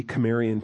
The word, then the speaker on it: Cimmerian: I think it's a hard C, Cimmerian people.